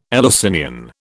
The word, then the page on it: Eleusinian: British English